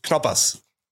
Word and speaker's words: Knoppers: Do we pronounce the K or not pronounce the K? – We pronounce it. – Knoppers.